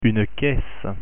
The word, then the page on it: caisse: French